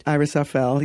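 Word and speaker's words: Iris Apfel: Am I pronouncing it right? – Iris Apple, yeah.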